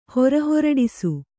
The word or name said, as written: ಹೊರಹೊರಡಿಸು